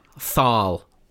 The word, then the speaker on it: Thal: So his first actual film credit was as, you're going to have to correct me on this, Tal in Doctor Who and the Daleks. Is it pronounced Tal? – How are you spelling that? – Thal. – Yeah, I think it's probably Thal. – Thal. Okay. Yeah.